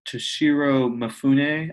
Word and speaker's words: Toshiro Mifune: And one of my favorite parts of the movie is the main character played by Toshiro Mifune, I think is his name. That's how you pronounce it.